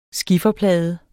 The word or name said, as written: skifferplade